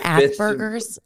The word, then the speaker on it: Asperger's: He's German. So like just say Asperger's. It with the German.